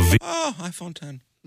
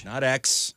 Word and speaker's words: iPhone X: iPhone 10.